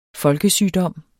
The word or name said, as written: folkesygdom